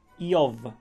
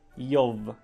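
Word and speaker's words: Jow (איוב): No więc po hebrajsku w oryginale nazywa się to Jow. Imię tego człowieka. Jow. B i W to jest w hebrajskim jedna litera.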